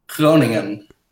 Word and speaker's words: Groningen: Come on, Groningen.